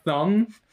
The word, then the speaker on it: thumb: I've been thinking about the silent B in the word thumb.